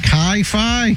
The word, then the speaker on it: Chi Phi: Chi Phi? Chi Phi Fraternity House. C-H-I, that's Chi.